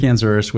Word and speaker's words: Osures: Well, first of all, thank you for pronouncing my last name perfectly. Great Spanish. Yes, I'm Frank Osures with Epson, part of the SD division for Epson, which is the small printing devices and the POS printers for our division.